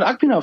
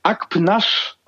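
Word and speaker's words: Akpınar: It turns out, äh, dank Recherchen mit Lukas Feldhaus, ähm, richtig spricht man den Nachnamen Akpnasch aus.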